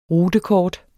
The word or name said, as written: rutekort